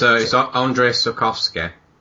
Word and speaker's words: Andrzej Sapkowski: It's Andrzej Sapkowski.